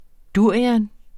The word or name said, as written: durio